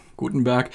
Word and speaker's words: Gutenberg: Diesen neuen Editor nennen sie Gutenberg oder Gutenberg, man darf das ruhig deutsch aussprechen. Den Buchdruck haben die Deutschen erfunden. Gutenberg.